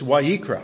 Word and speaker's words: Vayikra: And yet in the Hebrew version, it's not Leviticus, it's Vayikra. I'm speaking in tongues tonight. Vayikra.